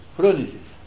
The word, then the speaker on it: Frônesis: É Frônesis ou Frônesis? – Frônesis, tá? É, eu que de vez em quando erro, né? Mas é Frônesis, o nome, a pronúncia é certa.